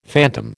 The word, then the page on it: phantom: American English